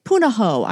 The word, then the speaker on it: Punahou: Did I pronounce the school correctly? – Punahou. – Punahou. I pronounced it incorrectly. Punahou. I said Punahou.